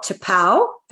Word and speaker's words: T'Pau: He was part of a popular band called T'Pau. Have I pronounced that right, Tim? – That's correct, yes.